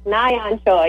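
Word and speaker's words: Na Yeon Choi: Choi Nahwan. Choi, am I mispronouncing her name completely? – Close. Nayan Choi.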